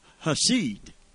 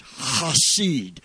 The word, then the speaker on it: Hesed: And the word that all of these other words that are so wonderful, powerful, and beautiful come from is H-E-S-E-D, a Hebrew word about that is in the very essence of God. Hesed. It's pronounced Hesed. That in the Jewish. Hesed. It's almost like you're clearing your throat, help you remember it.